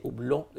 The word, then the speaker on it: Hublot: Y me gustan los Hublot, que mucha gente le decimos Hublot, lo que sea. Pero andando ahí en Europa, yo pregunté cómo se decía y se dice hublot.